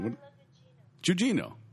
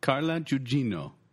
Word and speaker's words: Carla Gugino: Carla Gugino. I was right. – Play it again. – Carla Gugino.